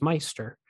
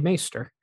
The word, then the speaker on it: Maester: When the royal steward greets Eddard and says that Grand Maester Pycelle has called a meeting of the small council, the steward mispronounces Maester as Meister. The correct pronunciation is actually Maester. This was a reportedly common mistake on the set, which took the cast some time to get right.